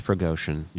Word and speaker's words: Prigozhin: His name is Prigozhin. – Prigozhin. – Looks like Prigozhin on paper. – I hear it pronounced Prigozhin.